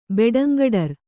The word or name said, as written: ಬೆಡಂಗಡರ್